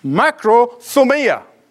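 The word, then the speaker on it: makrothumia (μακροθυμία): The Greek word is this, makrothumia. It's one word, but I'm pronouncing it as if it's two.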